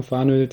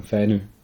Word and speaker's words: Fanø: Also die Deutschen sagen Fanö, die Dänen sagen Fänö.